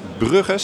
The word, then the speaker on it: Brugge: En we hebben een, eh, een, een gast uit Brugge, zeg ik dat goed?